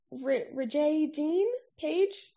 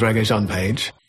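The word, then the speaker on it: Rege-Jean Page: And then, of course, the featured extra on the trailers was, I'm going to butcher his name, but he's Rajay Jean Page? – I think that's his name. – No, Mindy, that's not his name at all. Mindy from the future here. Kim's right. We are going to absolutely butcher this good man's name the entirety of this review. So to make up for it, I'm just going to let him introduce himself. – My name is Regajan Page.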